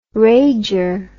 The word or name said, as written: rager